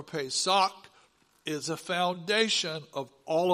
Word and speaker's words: Pesach: Pesach. One more time, Pesach. How do you learn to pronounce it? Well, you paid a good price for a sock.